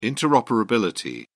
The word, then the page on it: interoperability: British English